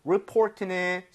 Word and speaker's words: report: 그리고 report는 사실 리포트, 레포트, okay? So 예. – 신고, 보고서가 되겠습니다. report 이렇게 발음할 때 내가 아니라 report. – Report. – 요거만 주의해 주세요.